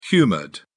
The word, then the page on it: humoured: British English